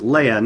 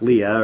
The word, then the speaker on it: Leia: she says, "Leia, not Leah, or anything like that." so it's Leia.